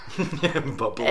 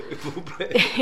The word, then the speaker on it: Bublé: Bublé. – And so I can't hear his name without thinking of Michael Bublé. – Bublé.